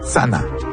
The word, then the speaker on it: channa: Par exemple, pour dire arrêt en abénaki, on dit sana. En abénaki, ça s'écrit C-H-A-N-N-A, mais il n'y a pas le son C-H. À la place, on utilise le son T-S. Et donc, ça donne Sana.